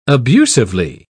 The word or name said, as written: abusively